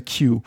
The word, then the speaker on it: Kew: So in the United Kingdom at Kew, and I like that it's like it's spelled K-E-W, but to me it's always just like the letter Q.